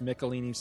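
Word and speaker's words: Michelinie: Now, I'm not sure exactly how you pronounce this guy's name. I've heard Michelini, I've heard Michelini, all kinds of different pronunciations. I usually said Michelini when I was younger, but everybody tells me it's Michelini, so I'm going to say Michelini.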